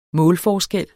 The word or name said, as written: målforskel